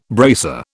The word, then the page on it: bracer: British English